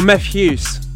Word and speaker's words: Mefjus: And then after that, we'll be going into the tune of the month of this month, which comes from Mephjus, or Mephjus, which is how you pronounce it.